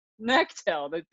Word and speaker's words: Knechtel: Nail that, Justin Faerman and Jackie Knechtel. Did I say that? – So you knew I was going to be on. You still said my last name wrong. It's Knechtel. – Knechtel. The K is silent.